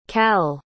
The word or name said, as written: kal